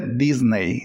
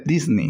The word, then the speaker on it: Disney: No se dice Disney, se dice Disney. – Disney. – Disney. Es la pronunciación correcta.